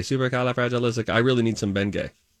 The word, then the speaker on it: Bengay: Because you put the emphasis on the wrong part. It's Bengay.